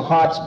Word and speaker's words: Hotze: As one of the gentlemen said, he bought the book 2 weeks ago so as to read about Henry Hotze, and I'm calling him Hotze because I think Hotze is correct, but there is a collateral branch of the family which is spelled H-O-T-Z, and I assume that would be pronounced Hotze, but anyhow, I'll call him Hotze for convenience.